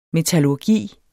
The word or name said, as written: metallurgi